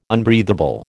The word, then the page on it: unbreathable: American English